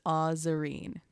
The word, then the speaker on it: Azareen: And so I wanted to like actually emphasize that pronunciation because a lot of the time people say like Azareen. – I say that. – Like Azareen, or they say like like Azareen. Like yeah. they don't like pronounce the A. – Yeah. – So the actual pronunciation of my name is Azareen.